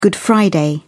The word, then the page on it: good friday: British English